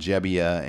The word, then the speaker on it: Gebbia: Now, is it Gebbia or Gebbia? I want to make sure I get this right. – It's Gebbia. – Okay, we've been saying Gebbia. Mm-hmm. We've been saying the kid's name wrong all year.